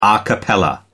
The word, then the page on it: a cappella: American English